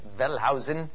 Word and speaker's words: Wellhausen: One reason I will mention, however, was a notion by Julius Wellhausen, or Wellhausen as he's pronounced in English, Wellhausen,